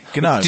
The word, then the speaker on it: GNOME: Dear sir, GNOME is pronounced with a hard G.